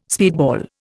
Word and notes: speedball: Indian

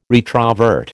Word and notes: retrovert: American English

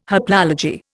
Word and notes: haplology: Indian

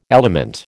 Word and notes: element: American English

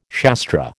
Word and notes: Shastra: American English

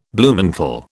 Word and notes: Bluemantle: British English

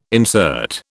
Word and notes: insert: British English

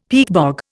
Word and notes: peatbog: Indian

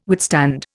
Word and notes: withstand: Indian

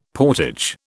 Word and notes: portage: British English

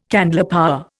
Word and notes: candlepower: Indian

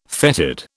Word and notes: fetid: British English

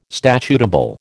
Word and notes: statutable: American English

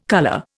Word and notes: colour: Indian

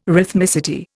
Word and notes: rhythmicity: Indian

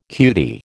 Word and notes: cutie: American English

